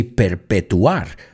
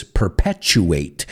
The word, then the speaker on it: perpetuate: You say perpetuar, but for us it's perpetuate. Acuérdate, ese T-U is ch, future, nature, perpetuate.